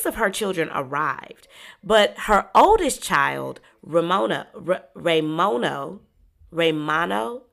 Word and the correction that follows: Ramon: how do you say that, Steph? Raymono or Raymono? – It's Ramon. – Ramon? Mm-hmm. Oh, I had it all wrong.